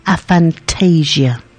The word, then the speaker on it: aphantasia: So it's called, let's try that pronunciation again, aphantasia.